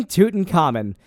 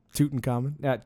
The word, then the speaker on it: Tutankhamun: So, King Tutankhamun lived from 1341 BCE to 1323 BCE. – I like that pronunciation so much better. – Died around what? – Tutankhamun. – Tutankhamun.